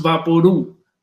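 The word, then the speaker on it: VapoRub: Vaporu is pronounced Vaporu, like Lao or Chu.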